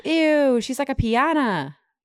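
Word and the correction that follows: piñata: did I say that right? Pinata. Pinata. Did you say pinata? – Maybe.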